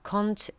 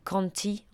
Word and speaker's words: Conte: Bref, on vient d'écouter le groupe Pamplemousse, qui est à la base un couple très charmant composé de Jack Kant et Natalie Dawn. En fait, je pense que ça se prononce Jack Conti en anglais. Ça s'écrit comme un conte en français.